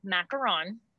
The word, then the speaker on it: macaron: So it is macaron. – Okay. – Like you're saying Ron from Harry Potter at the end, okay.